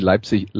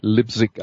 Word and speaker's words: Leipzig: Übrigens, üb Übrigens, äh, was ich noch, was ich noch sagen wollte, nur so als äh, äh kleine Randnotiz: In England gibt es übrigens viele Menschen, die Leipzig Lipzig aussprechen.